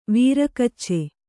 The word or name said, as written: ವೀರಗಚ್ಚೆ